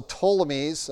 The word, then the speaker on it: Ptolemies: And so the Ptolemies, it starts with the P, P-T, but the P is silent, were Egypt.